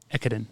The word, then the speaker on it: Ekiden: Hakone Ekiden? – Ekiden.